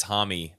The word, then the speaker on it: Homme: Which doesn't, I mean, there's a lot of people that once they realize it's Homme, they go, wow, that doesn't sound as cool. But yeah, it's Josh Homme.